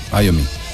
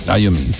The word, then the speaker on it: Iommi: Creo que lo dices mejor, Iommi. Iommi, bueno, sí, se escribe así, ¿no?